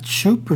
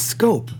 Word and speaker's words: Schoop: Is it Schoop or Scoop? – I like to call him Schoop. – No, it's Scope. – It's Scope? – Yes.